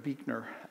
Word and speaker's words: Buechner: One of my favorite authors is Friedrich Buechner.